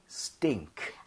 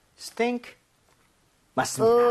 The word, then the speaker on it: stink: Stink. – 아! – 아하. – Sting, sting, stink. – Okay. Stink. 맞습니다.